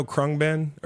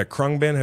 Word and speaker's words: Khruangbin: Do you know Khruangbin? Or am I saying that right? Yeah, Khruangbin. Have you ever heard of them? – Khruangbin.